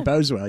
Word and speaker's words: Boswell: How do you say his name? I can't I can't screw it up. Boswell? Uh, Boswell, you got it.